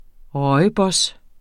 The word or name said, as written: rooibos